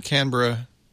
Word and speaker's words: Canberra: Rana Omar, who's in Canberra, hope I pronounced that right, Canberra, Australia? – Yeah, Canberra.